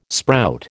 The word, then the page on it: sprout: American English